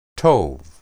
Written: tob

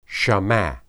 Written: shama

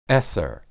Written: eser

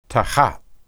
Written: tahath

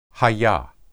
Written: hayah

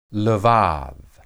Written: lebad

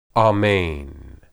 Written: amen

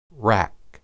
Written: raq

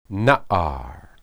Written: naar

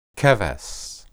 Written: kebes

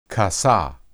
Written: kasah

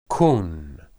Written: kun

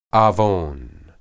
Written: awon

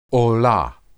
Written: olah

